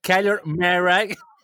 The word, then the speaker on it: Kyler Murray: Fíjate cómo lo pronuncio. Kyler Murray.